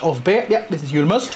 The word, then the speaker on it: Julmust: I'm probably going to butcher this, but Julmust? Did I say that right? – Julmust. – Julmust. – Yes.